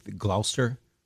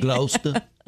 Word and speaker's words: Gloucester: Hey, where's Gloucester? Yeah. You mean Gloucester? No, read it. It's spelled Gloucester.